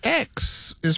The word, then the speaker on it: Xi: The X is pronounced like SH.